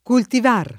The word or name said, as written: cultivar